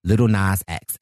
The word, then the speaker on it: Lil Nas X: Yes, Lil Nas X.